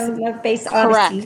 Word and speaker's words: Stone Love Bass Odyssey: John has been doing his, how do I say it right? Stone Love Bass Odyssey. – Stone Love Bass Odyssey. – Correct.